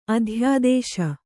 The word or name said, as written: ಅಧ್ಯಾದೇಶ